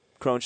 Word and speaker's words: Cron: And by the way, Baseball Reference doesn't have a pronunciation of his name, so it's Crone. I promise you. – And well, they should put it up there. I was going to go Cron.